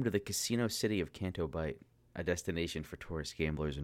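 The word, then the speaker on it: Canto Bight: Yeah, it's Canto Bite.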